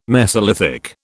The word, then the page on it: mesolithic: British English